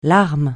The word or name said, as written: larme